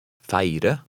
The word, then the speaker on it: feire: Remember that number 10 has 2 syllables. Try to read number 10. – Feire.